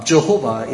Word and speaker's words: Jehovah: Many pronounce this Jehovah as Yahweh, which is a literal pronunciation of the name of God in Hebrew. But out of due respect to his honor, Jehovah is properly said.